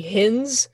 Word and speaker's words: Hins: My married last name has its moments where people look at it and they think it's like Heinz, like the ketchup, where it's actually Hins.